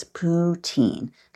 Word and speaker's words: poutine: Poutine, but I think it's poutine. Like poo, you have to go to the bathroom. Teen, like when you're really a rotten 16-year-old, you're a teen, bad teen. Poutine, I think you kind of say it like that.